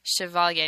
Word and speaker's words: Chevallier: Now, let me spell that for you, because I know that he kind of gets a lot of different pronunciation, so I got to do him justice. C-H-E-V-A-L-L-I-E-R. Chevalier. – It's a Chevalier.